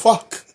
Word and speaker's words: FCUK: That's FCUK. You can't pronounce that. – It's fuck. – How do you pronounce that? – Fuck. F-C-U-K.